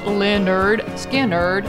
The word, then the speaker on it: Lynyrd Skynyrd: September 13th, 1973, Lynyrd Skynyrd releases, pronounced Lynyrd Skynyrd, their debut album.